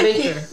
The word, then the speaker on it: Baker: He became a major voting rights activist in Mississippi and Alabama after being mentored by Ella Barker. Am I saying that right? I can't see. – Baker. Ella Baker. – Is it Baker? Okay. – Yeah, Ella Baker.